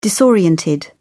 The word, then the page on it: disoriented: British English